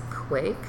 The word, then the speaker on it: kveik: It's Quake, so it's spelled K-V-E-I-K, and it's a Norwegian yeast, mm-hmm.